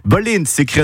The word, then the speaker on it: valent: Baleine s'écrit en occitan V-A-L-E-N-T, donc la lettre V qui se prononce B comme en catalan et en espagnol.